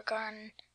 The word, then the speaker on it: garden: Okay, you need to pronounce, enunciate the D. You're saying garden, which is what I do too when I talk.